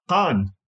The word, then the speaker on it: Han: Han.